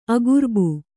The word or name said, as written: ಆಗರ್ಭ